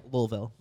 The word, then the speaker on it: Louisville: Well, I mean, you know how to say it, right? – Louisville. – Like you got a dick in your mouth.